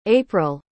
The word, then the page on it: April: English